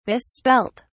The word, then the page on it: misspelt: British English